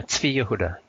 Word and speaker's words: Zvi Yehuda: I'm speaking about the products of Zvi Yehuda, or I believe it's rightly pronounced as Zvi Yehuda, right? Correct me if I'm wrong. – Yeah, Zvi Yehuda, yeah.